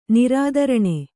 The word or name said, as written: ನಿರಾದರಣೆ